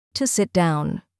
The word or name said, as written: to sit down